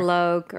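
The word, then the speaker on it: Loké: Loque, it rhymes with rosé, so you're doing perfect. – Okay, because L-O-K-E.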